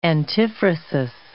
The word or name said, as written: antiphrasis